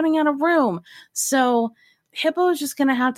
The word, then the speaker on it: Hippeaux: Hippo is spelled H-I-P-P-E-A-U-X.